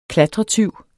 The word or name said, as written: klatretyv